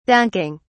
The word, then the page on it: dunking: British English